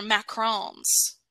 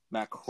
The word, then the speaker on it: macaron: I believe those are macarons. – Macarons? Yeah. – Macarons. That's how they're pronounced.